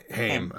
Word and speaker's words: Haim: She's from the band, how do you pronounce it? It's their last, the Three Sisters, Haim. And it's their last name. Is it Haim? I thought it was maybe Haim. – Yeah. – Haim. Okay.